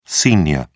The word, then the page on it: sr: British English